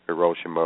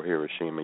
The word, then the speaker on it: Hiroshima: with the detonation of Little Boy over Hiroshima or Hiroshima, you can pronounce it either way, quickly ended the war.